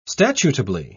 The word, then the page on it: statutably: British English